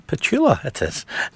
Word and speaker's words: Petula: I used to say her name wrong for so many years. Petunia. Petula, it is.